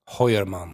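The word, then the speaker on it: Heuermann: Hoyerman.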